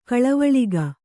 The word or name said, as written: ಕೊಳವಾಳಿಕೆ